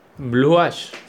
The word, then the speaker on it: Blue Ash: Se llama, eh, no sé si lo estoy pronunciando bien, pero es Blue Ash. Blue Ash. Sí. Blue Ash. – Algo así. Como Ash de A-S-H.